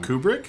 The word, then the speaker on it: Kubrick: I always say his last name wrong. Kubrick? – Kubrick. I don't know why I always want to say like Kubrick, but Q. It's like Kubert, like Q- Stanley Qbert. Beep, beep, beep.